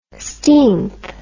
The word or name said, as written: steenth